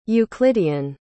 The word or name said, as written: euclidean